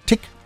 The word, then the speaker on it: Tich: T-I-C-H wird Tick ausgesprochen. Ja, so wie Tick-Tack, Tick-Tock, Tack, Tick-Tack, Tick.